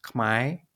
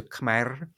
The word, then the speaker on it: Khmer: First, so this term Khmer or Khmai, these are just different pronunciations of the same word in the Cambodian language. It's as pronounced in Cambodia in most dialects, it's pronounced Khmai, and that pronunciation is often favored in Cambodian diaspora communities. In other dialects, particularly dialects spoken in Cambodian spoken in Northeast Thailand, the pronunciation may be closer to Khmer.